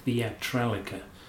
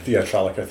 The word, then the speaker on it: Theatralica: which is why he calls it Theatralica. That's the way I'd pronounce it anyway. – Theatralica, thank you.